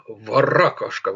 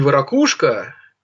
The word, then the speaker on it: варакушка: Варакушка, варакушка. А, кстати, не варакушка, не варакушка, а именно варакушка. Вот запомните, как ставится ударение.